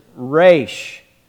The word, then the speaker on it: resh: The Hebrew letter is resh. It's pronounced this way. Resh.